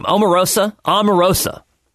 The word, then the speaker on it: Omarosa: Omarosa. That's how you pronounce it.